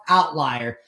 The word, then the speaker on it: OVTLIER: That is how it's spelled, but it's pronounced outlier.